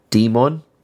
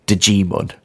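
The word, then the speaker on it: Djimon: I'm guessing Silent J, so Demon. But if you pronounce the J, it's Digimon. – Yeah, it's probably either G-mon or Demon.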